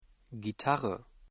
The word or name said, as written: Gitarre